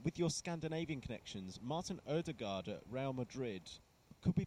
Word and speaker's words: Ødegaard: Odegaard.